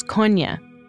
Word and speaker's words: Kony: There are, um, you know, it has to do with, with linguistic differences in southern Uganda, why people call him Kony. The proper pronunciation is Konya. – It's sort of like cognac, always telling about the advocacy movement that they never pronounced his name correctly.